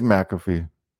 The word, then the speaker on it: McAfee: It is McAfee. I always say McAfee.